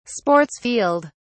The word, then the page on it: sports field: British English